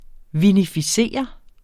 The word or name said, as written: vinificere